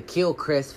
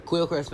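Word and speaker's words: Quellcrist: It's a K, y'all. It's a Q, how you actually spell her name, but it's pronounced with, like, a K. It's weird. I don't know. But Kilcrest Falconer. To be honest, if you say the quill part, you really gonna fuck yourself up.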